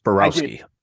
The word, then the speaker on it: Borowski: Yes, Borowski.